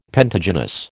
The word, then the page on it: pentagynous: American English